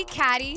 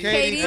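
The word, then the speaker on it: Cady: I'm going to be Catty. Is that how you say it? – Katie.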